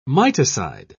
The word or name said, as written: miticide